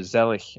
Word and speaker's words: Zelig: We're going to talk about 1983 Zelig, or Zelig, if you want to pronounce it correctly, in a German pronunciation.